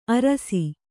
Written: ಆರೈಸು

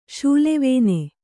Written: ಶೂಲೆವೇನೆ

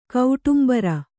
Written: ಕೌಟುಂಬರ

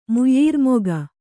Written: ಮುಯ್ಯೀರ್ಮೊಗ

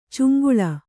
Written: ಚೆಂಗೂಲಿ

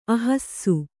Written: ಅಹಸ್ಸು

